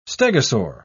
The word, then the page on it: stegosaur: British English